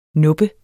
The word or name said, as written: nuppe